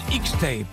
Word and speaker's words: Hixtape: C'est un groupe qui s'appelle X-Tape, mais attention, ça s'écrit H-I-X-T-A-P-E. X-Tape avec Midland à ses côtés et Marty Stewart également.